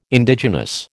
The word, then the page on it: Indigenous: American English